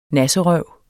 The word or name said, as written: nasserøv